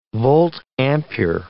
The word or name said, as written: volt-ampere